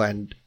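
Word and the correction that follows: Ende: I think it's pronounced Ender, but I'll let you have it. – Ender. Question three. – Pronounced Michael Ender if he's German, but anyway, yes, correct.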